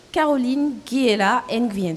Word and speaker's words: Caroline Guiela Nguyen: Caroline Guiela Nguyen. Je le prononce bien Ouais, super. Perfect.